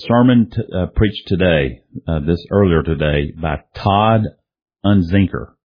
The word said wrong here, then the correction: Unzicker: Unzicker, I believe that's how you pronounce his name.